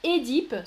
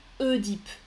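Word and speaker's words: Œdipe: Alors, vous votez, là beaucoup me disent Édipe, super, ça c'est la prononciation classique, Édipe. Mais beaucoup de gens, même moi, hein, je dis Oedipe aussi. Maintenant,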